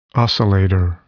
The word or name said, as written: oscillator